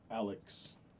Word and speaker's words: Alyx: Half Life Alec. Did I say it right? Alex. Alex. Oh my bad.